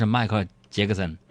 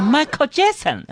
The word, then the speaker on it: Michael Jackson: 弟弟白了一眼杨哥，就说了：“ 哥，他就是迈克尔· 杰克森。” 迈克尔· 杰克森，这发音真土，那叫 Michael Jackson 啊。